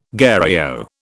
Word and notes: gherao: British English

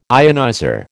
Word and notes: ionizer: American English

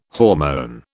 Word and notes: hormone: British English

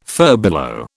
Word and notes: furbelow: British English

